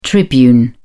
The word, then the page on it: tribune: British English